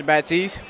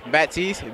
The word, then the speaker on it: Batiste: Elijah Baptiste, 14. – Spell it for me. – Elijah, E-L-I-J-A-H, Baptiste, B-A-T-I-S-T-E.